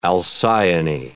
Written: Alcyone

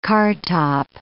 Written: cartop

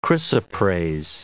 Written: chrysoprase

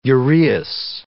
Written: uraeus

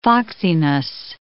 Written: foxiness

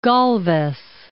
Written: Galvez

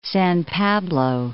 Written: San Pablo

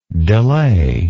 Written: delayingly

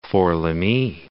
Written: Fort-Lamy

